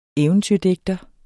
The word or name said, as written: eventyrdigter